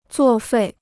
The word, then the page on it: 作废: zuò fèi